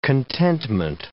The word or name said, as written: contentment